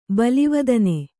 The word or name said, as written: ಬಲಿವದನೆ